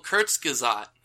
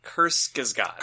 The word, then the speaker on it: Kurzgesagt: Oh, Kurzgesagt. – Kurzgesagt.